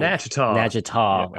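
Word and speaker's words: Natchitoches: Nagitaw.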